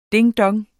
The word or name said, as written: ding-dong